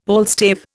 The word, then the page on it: palstave: Indian